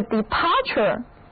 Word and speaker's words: departure: 这个 departure, D-E-P-A-R-T-U-R-E, 这个是 depart 的名词形式。因为 U-R-E 是一个名词后缀， T-U-R-E 在这里的读音是 chur， Departure 意思就是离开、出发。